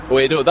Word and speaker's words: Oedo: Oedo, that's how you say it, Oedo. A man told me.